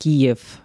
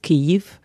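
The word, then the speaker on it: Kiew (Київ): Viele sind aus Kiew, weil richtig spricht man Kiew, nicht Kiew aus. Kiew ist auf Russisch, auf Ukrainisch ist Kiew.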